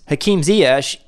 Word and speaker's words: Hakim Ziyech: Like it comes up, and it's Hakim Ziyech in the Netherlands when he was with Ajax being asked this question and saying Hakim Ziyech.